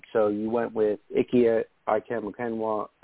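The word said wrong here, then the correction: Ekwonu: Ooh, geez. I always butcher his name. Poor guy. Aquanu, thank you.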